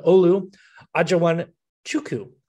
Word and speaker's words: Olu Ajanwachuku: A big welcome to Olu Ajanwachuku.